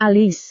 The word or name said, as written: alis